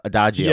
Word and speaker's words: Adagio: And he's like, yeah, but I only gave him the Adiago, you know, Adagio.